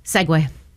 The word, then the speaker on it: segue: S-E-G-U-E. – Say that one more time. – S-E-G-U-E. – Oh, Segway.